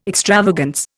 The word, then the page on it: extravagance: Indian